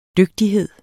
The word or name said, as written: dygtighed